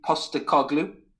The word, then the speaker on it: Postecoglou: He's got manager Ange Postecoglou.